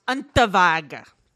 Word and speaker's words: Unterweger: Okay, he's Austrian. It's pronounced Unterweger. – Yeah, Unterweger. Whatever. But I don't like his name. – It's spelled Unterweger or Unterweger, but it's Unterweger.